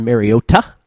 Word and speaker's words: Mariota: It is Marcus Mariota, as Mike Tirico likes to pronounce it, the correct Hawaiian pronunciation.